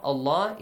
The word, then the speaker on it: Allah: Allah is pronounced heavily.